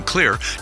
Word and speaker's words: Xlear: Also, you can find all of Clear's xylitol-containing products, that's the Clear nasal and dental products, because they've got your bases covered. Oh, by the way, Clear as in clear is spelled X-L-E-A-R.